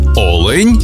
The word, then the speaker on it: олень: І в однині, і в множині наголос падає на перший склад – олень та олені.